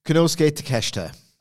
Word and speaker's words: Konosuke Takeshita: Takeshita? – Konosuke Takeshita.